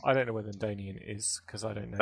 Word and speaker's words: Danian: Is that how you say it? Danian? – Yeah, that's how Danian.